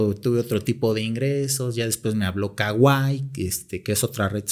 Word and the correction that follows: Kwai: Kwai se, pronuncia. Kwai.